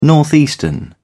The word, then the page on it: northeastern: British English